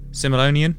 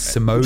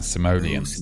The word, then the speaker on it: Simoleon: That's not the right pronunciation of it, though. – Rico, Commander Rico. – Yeah, that's not. It's simoleon. – Would you like to give us the correct? – Yeah, simoleon.